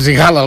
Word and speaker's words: Sigala: Després vam decidir que hem de dir Saigala. – Que és la pronunciació en anglès. – La pronunciació en anglès, exacte.